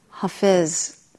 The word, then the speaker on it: Hafez: This is Hafez, trying to pronounce it correctly now after teaching you years of the wrong pronunciation.